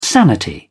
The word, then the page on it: sanity: British English